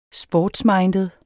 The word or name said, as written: sportsminded